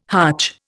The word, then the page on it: hutch: Indian